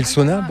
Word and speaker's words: sauna: Et le sauna, peut-être aussi. – Oui. – Le sauna. – Sauna, voilà. – J'ai appris maintenant qu'il fallait prononcer chaque lettre.